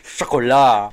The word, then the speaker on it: Chocolat: I pronounced it all foreign. Chocolat.